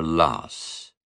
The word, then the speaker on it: Law: The confidants of the regent spread abroad his praise, and everyone expected great things of Monsieur Loss. The French pronounced his name in this manner to avoid the un-Gallic sound aw.